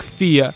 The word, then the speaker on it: Fe: И, в общем, с их а, такого языка это читается не как фе, а как фия.